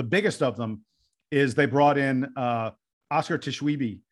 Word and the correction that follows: Tshiebwe: um, whose name I'm probably mispronouncing, from West I Virginia. think it's Shibwe. – Shibwe? Okay. – Yeah, Shibwe. Oscar Shibwe. – Shibwe.